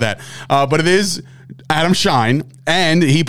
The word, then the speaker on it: Schein: Is it Shane? – It's Adam Schein. I know that for a fact because I used to work at Sirius.